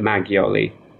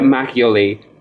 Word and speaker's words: makgeolli: Yeah, so actually, I mean, the middle of the word makgeolli is not pronounced gyoru. They've sort of shortened the word a little bit. So they've taken part of that word, the gyo bit, makgeolli.